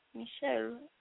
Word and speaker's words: Michel: Michelle Smeets is the Belgian game developer. Am I saying that wrong? Because that's a dude. Michel. Anyway. – Michel. – right. Make it sound French.